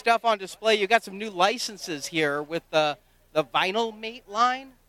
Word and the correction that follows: Vinimates: Yeah, Vinimates, actually. It rhymes with Minimates because we use that same sort of block figure format and translate it into a 4-inch dynamically posed figure.